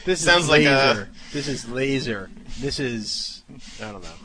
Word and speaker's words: Lazor: Moving over to the Miami Dolphins, they have a new offensive coordinator, Bill Lazor, or Lazar. You probably know how to say it better than I do. – I believe it's Lazor.